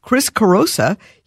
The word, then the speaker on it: Chris Carosa: Chris Carosa.